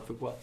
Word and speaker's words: Fuqua: And I heard an interview with Antoine Fuqua. Am I saying that right? Antoine Fuqua? – Yeah.